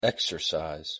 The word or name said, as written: exercise